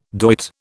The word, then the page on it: doit: British English